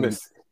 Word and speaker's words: Abmas: Well, they're going to see that on Monday against Oral Roberts and Max Atmus, who has shown Atmus. It's pronounced Atmus.